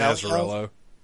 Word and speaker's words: Azzarello: Brian Azzarello.